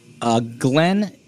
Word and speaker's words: Glynn: His name is Glenn, and it's spelled G-L-Y-N-N, Glenn Simmons.